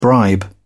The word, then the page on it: bribe: British English